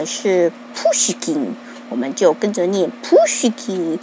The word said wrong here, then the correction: Pushkin: 没想到呢，俄罗斯的大妈就纠正我们发音，说 No No No No，Pushkin，Pushkin，嗯。